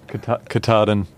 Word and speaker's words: Katahdin: Katahdin.